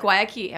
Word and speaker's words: Guayaquil: Guayaquil. Am I saying that right? – You said it right.